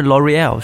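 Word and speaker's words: L'Oréal: L'Oreal 是很美美国人的发音，L'Oreal。嗯。但是如果它是真的念成法语词的话，我我试着念一下啊，L'Oreal。但肯定我念的不标准了。但是 L'Oreal 是对的，因为我有一次特别囧，我是想在。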